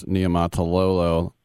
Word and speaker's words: Niumatalolo: You talk about, as they call him, Coach Nemo, and as you correctly pronounce, Niumatalolo.